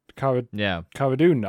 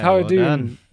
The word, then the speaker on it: Cara Dune: Cara Dune. I said it right this time. – Yeah, Cara Dune.